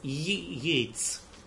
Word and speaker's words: Йейтс: Фрэнсис Йейтс. И краткий первый.